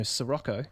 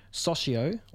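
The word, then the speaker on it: Soccio: Forever until today, I thought it was Gino Sirocco, like the Volkswagen, but it's not. So Gino Socio, or Socio, his tune Dance to Dance from the album Outline.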